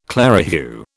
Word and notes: clerihew: British English

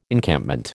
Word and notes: Encampment: American English

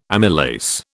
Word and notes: amylase: British English

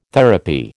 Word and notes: therapy: American English

